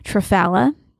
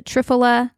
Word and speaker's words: Triphala: Another supplement I do want to recommend that I absolutely love is called Triphala. T-R-I-P-H-A-L-A. Some people pronounce it Triphala, Triphala, whatever.